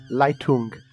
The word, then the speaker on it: Leitung: Did I say that right? – Leitung. – Leitung, yes.